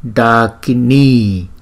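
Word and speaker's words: Ḍākinī: Chữ D có dấu nặng thì chúng ta phải đọc là D, D, D. Nó từ trong cuống họng chúng ta đọc ra. Chúng ta đọc phải nhấn mạnh và cái lưỡi phải cong. Daka, Daka, Daka, Dakini Graha.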